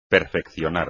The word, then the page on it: perfeccionar: espagnol